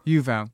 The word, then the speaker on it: Yuval: So let's talk to Yovil Harari. I'm saying your name correctly, Yovil. – Yuval. Yeah, like it's written, there is no secret there.